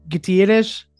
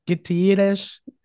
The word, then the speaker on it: Gutierrez: And I'm delighted to be joined here by Emiliano Gutierrez. Did I say it correctly? Gutierrez? – More 90% correct. That's all good.